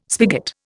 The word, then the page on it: spigot: Indian